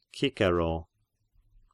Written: Cicero

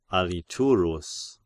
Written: Aliturus

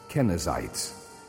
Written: Kenizzites

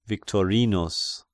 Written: Victorinus

